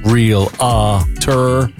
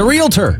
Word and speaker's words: realtor: There's no real-a-tor. – It's a realtor.